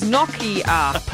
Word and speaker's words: Nokia: The correct pronunciation of Nokia is Nokia.